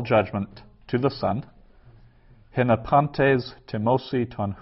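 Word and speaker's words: timosi (τιμῶσι): So the timosi there, that's also subjunctive, so it's got a long O, an omega instead of timusi would be they honor, timosi they might honor.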